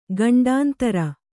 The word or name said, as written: ಖಂಡಾಂತರ